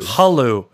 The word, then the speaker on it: Hulu: What'd you say with the all the emphasis on the H? – Yeah, it's the Hulu. – Hulu. It's Hulu.